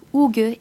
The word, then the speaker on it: hygge: Donc, ça s'écrit Hugge. – H-Y-G-G-E, mais ça se prononce Hugge, et c'est du danois.